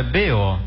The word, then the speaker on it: B.O.: Um bó, o senhor disse bó, não é bó, é B.O.